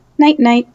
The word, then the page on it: night night: English